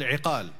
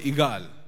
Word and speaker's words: iqal: So those ropes, they are till today called iqal. But in Amiya, they call it igal, because the qaf, they pronounce it like a gaf.